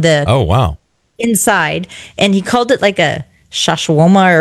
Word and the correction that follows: schwannoma: or something. I don't know what it is. – Schwannoma? – Schwannoma? Is that how you say it? – Yeah, that I've got one of those.